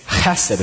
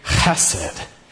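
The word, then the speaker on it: chesed (חסד): So it's chesed, and you really got to get down there. So let's say that together. 1, 2, 3, chesed. It really feels like faithful, steadfast love when you say it like that.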